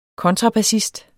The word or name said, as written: kontrabassist